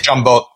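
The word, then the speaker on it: giambotta: I go, how do you say it? I always get yelled at for saying it wrong. Jambot? – Jambot. Yeah, that's how we said it growing up.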